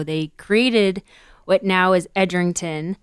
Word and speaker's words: Edrington: Did I say that right? – Edrington. – Edrington. Yeah.